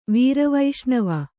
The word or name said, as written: ವೀರವೈಷ್ಣವ